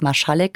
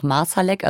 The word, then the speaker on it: Marsalek: Zum Beispiel weiß man ja gar nicht, wie sein Name richtig ausgesprochen wird. Ne? Du hast jetzt Marschalek gesagt, ich auch. Manche sagen auch Marsalek.